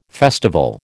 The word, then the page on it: festival: American English